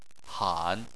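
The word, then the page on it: han: tone 3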